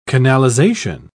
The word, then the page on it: canalisation: British English